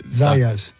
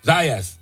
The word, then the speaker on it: Zayas: Zayas. – Zayas.